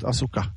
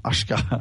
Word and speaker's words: Asuka: Och så har de ju dessutom Bailey mot Asuka, eller Ashka. Jag, jag fattar inte hur de kan uttala det som Ashka. – Nej, jag säger också Asuka och så får hon heta i min värld i alla fall.